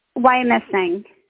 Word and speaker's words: Wyomissing: Now, Wyoming, how do you say it, Wyomissing.